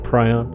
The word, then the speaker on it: prion: I like prion. I like saying prion. – Sounds more Battlestar Galactical. – Yeah, you can get online and ask for the pronunciation, and you'll find it both ways, prion or prion.